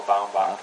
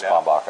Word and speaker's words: Baumbach: He Tenenbaums and A Life Aquatic were both co-written with a guy named Noah Baumbach. Mm-hmm. Oh, I'm probably mispronouncing. – And, it's Baumbach, I think.